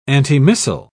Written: antimissile

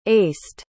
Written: aced

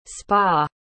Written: spaw

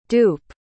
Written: doupe

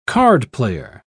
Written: cardplayer

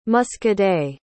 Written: muscadet

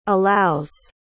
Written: allows

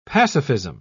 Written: pacifism

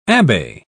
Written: abbe

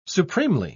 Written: supremely

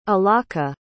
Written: alaka

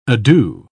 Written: ado

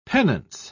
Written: penance

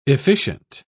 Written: efficient

